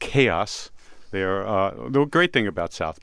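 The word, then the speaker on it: KAO=S: It's spelled K-A-O. There's an equal sign and then an S. And through the magic of the internet, I wrote to them this morning, and in fact, it's pronounced Chaos.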